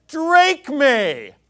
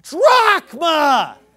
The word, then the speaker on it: drachma: But he's going back to the Greek, trying to sound smart, but he ends up sounding like an idiot because he says, drachme. Hey, dipstick, it's pronounced drachma.